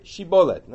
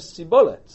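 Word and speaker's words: shibolet (שיבולת): Now the Ephraimites had a different dialect of Hebrew, and their shins were pronounced as sins. Vayomer sibolet. So they would say sibolet. V'lo yachinu daber kein. They couldn't pronounce the word shibolet correctly.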